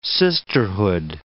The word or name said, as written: sisterhood